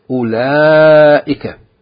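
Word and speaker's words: أولئك: Note that the wow in أُولَٰئِكَ is not pronounced. Yani, the wow in the beginning of the word, أُولَٰئِكَ, we don't pronounce the wow. It's just أُولَٰئِكَ. The word is pronounced أُولَٰئِكَ.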